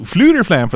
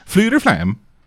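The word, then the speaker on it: Fflewddur Fflam: Yeah, and so Fluterflam, for those who don't know, is spelled FFLEWDDURFFLAM. Fluterflam.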